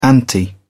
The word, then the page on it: anti: British English